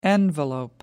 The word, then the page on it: envelope: American English